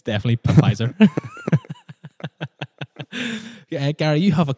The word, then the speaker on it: Pfizer: Pfizer. – Oh, wow. – With a silent P. – Yeah, because written down here, it looks ridiculous. – It's a strange word. – P-F-I-Z-E-R. And you're saying it's Pfizer? – Pfizer.